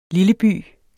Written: lilleby

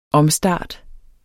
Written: omstart

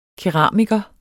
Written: KERAMIKER

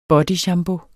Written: BODYSHAMPOOEN

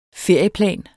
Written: ferieplan

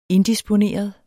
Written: indisponeret

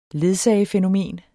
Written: ledsagefænomen

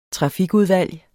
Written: trafikudvalg

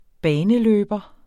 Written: baneløber

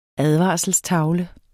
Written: advarselstavle

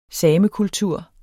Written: samekultur

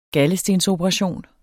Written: galdestensoperation

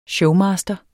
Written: showmaster

